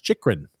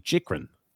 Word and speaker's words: Chychrun: And then Scott L says it's pronounced Chychrun. Thank you. Call him Chichrin, but thank you, Chychrun.